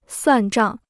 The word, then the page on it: 算账: suàn zhàng